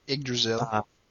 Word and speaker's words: Yggdrasil: When, after they, it was in The Alchemist during the battle at the Yggdrasil, or however you pronounce that tree. – Yggdrasil.